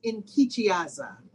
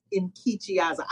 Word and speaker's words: Nkechiaza: Yes, it's actually pronounced Nkechiaza. In some translations, the N is silent.